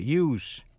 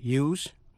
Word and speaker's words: use: Vậy chữ S trong danh từ use hay trong tính từ useful hay là useless? Chữ S đọc với âm S. Khi là động từ use thì chữ S phát âm là Z.